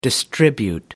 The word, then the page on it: distribute: American English